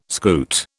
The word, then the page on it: scoot: British English